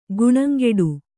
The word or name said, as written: ಖಾಣಂಗಟ್ಟು